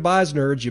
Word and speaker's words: Beisnerds: That's at B-E-I-S-N-E-R-D-S, B-E-I-S-N-E-R-D-S. That's pronounced Beisnerds, like nerds, but with B-E-I-S in front of it.